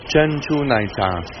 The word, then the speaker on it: 珍珠奶茶: 珍珠奶茶。 – 珍珠奶茶。